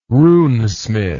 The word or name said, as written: runesmith